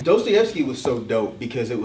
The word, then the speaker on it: Dostoevsky: Even though I love Black Dot, but he mispronounces his name. I thought it was funny. I think he called like Dostoyevsky. It's Dostoyevsky.